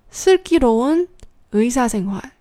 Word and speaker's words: 슬기로운 의사생활: 先慢一点，一个字一个字的读。 su, ki, ro, un, ui, sa, sen, hui，然后再连起来。 s ki ro un, ui sa sen hui。